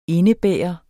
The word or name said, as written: indebære